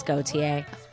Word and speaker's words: Gauthier: It's because nobody can pronounce Gauthier.